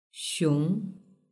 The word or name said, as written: xiong